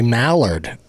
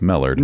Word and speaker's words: Mellard: M-E-L-L-A-R-D. Millard. – Yeah, you've got the spelling right. You're just putting the emphasis on the wrong syllable. – Yeah. – What's the spelling? – M-E-L-L-A-R-D. It looks like Millard, but it's Millard.